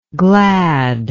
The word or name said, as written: gladness